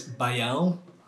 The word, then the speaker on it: Baião: I'm going to pronounce it because Miriam can't do it for me, but it's Baião.